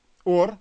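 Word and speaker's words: Ор (אור): Точно так же звучит слово еще одно. Оно читается как ор, но переводится оно как кожа. Заметьте, два слова читаются как ор.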